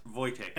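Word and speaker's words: Wojtek: Today we're going to discuss the story of Corporal Wotjek. Now this is actually spelt, if you're using a standard English phonetic alphabet, Whiskey, Oscar, Juliet, Tango, Echo, Kilo. However, it is a Polish name and is pronounced Wotjek.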